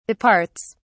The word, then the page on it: departs: British English